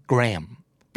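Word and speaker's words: Graham: จริงๆแล้วชื่อนี้ต้องอ่านว่าแกรมเปรียบเสมือนไม่มีตัว H เลยนะ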